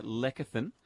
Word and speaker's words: lecithin: Try Healthy Care Lecithin from Chemist Warehouse. Lecithin, am I saying this correctly? Lecithin, Gerald? – Yes, lecithin. – Lecithin. – It's a hard C.